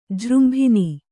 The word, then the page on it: ಜೃಂಭಣ: Kannada